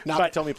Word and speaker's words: Nakatomi: I didn't want to mispronounce it because I saw. No, it's okay. Nakatomi Plaza. Yes. – Yeah, Nakatomi. Okay. Well, I wanted to add an extra syllable.